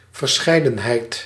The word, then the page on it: verscheidenheid: /vǝɾ.sχɛj.dǝn.ɦɛjd/